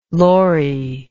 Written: lorrie

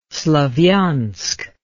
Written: Slavyansk

